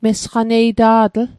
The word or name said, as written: mesaqaneita'tl